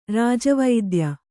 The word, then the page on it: ರಾಜವೈದ್ಯ: Kannada